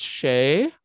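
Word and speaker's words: sidhe: It's pronounced "shay," mm-hmm. but it's spelled S I D H S. So SIDS, you would think, mm-hmm.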